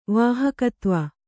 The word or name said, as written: ವಾಹಕತ್ವ